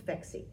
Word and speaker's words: Phexxi: So here's very quick, and I won't be too verbose about this, but the product's called Phexxi, but it's spelled P-H-E-X-X-I. – Which I love the name. – So thank you. But it's not just because it rhymes with sexy.